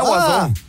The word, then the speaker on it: Oizo: Jeg kan se her, der står pronunciation. – Ja. – Wazo. – Det er Wazo. – Der står på wiki her. – Okay. – Wazo. Fransk selvfølgelig.